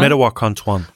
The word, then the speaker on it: Mdewakanton: die Medawakantong Dakota, ist das richtig, so ungefähr? Hilf mir nochmal. – Medawakanton. Okay. – Medawakanton.